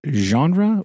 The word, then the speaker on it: genre: Do you pronounce it genre or genre? – Genre. Soft G. – Okay. Soft G. I know a lot of people say it with the hard J sound.